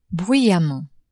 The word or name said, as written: bruyamment